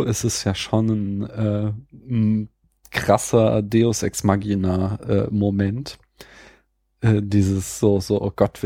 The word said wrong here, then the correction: Deus Ex Machina: Ist nicht Machina? – Ja, Deus, ich hab's hessisch weich ausgesprochen schon. Deus Ex Machina.